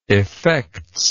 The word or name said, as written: effects